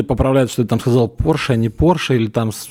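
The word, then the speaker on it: Porsche: Да вот ищите, блядь, Е40, а, ебаный, а, До Стаса же также доебались, он когда себе купил Кайман, он сказал Порш. И ему там все Порше, Порше, правильно говорить, Порше.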